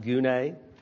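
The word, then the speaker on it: gune (γυνή): Specifically, whether the certain word, it's G-Y-N-E, it would be pronounced gune.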